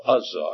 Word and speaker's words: Uzzah: If you look in the book of 2 Samuel, you'll find the story there of a man by the name of Uzzah, I believe is the way his name is pronounced, U-Z-Z-A-H.